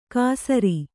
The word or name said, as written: ಘಸರಿ